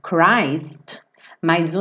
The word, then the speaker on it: Christmas: Só lembrando essa pronúncia do Christmas, que embora Cristo, ou seja, se você tirar o M A S, você vai pronunciar Christ, mas o Natal é Christmas, tá bom? Não é Christmas. Então, Christmas, so this is Christmas.